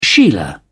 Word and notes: sheila: British English

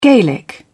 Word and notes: gaelic: British English

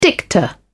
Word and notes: dicta: British English

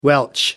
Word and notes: welsh: American English

